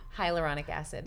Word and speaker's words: hyaluronic acid: And you mentioned using, you know, vaginal moisturizers with how do you pronounce it? – Hyaluronic acid.